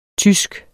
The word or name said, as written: TYSKE